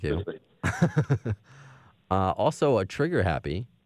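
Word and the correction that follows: Trigger Hippy: Trigger Hippie. – Trigger Hippie. – H-I-P-P-Y.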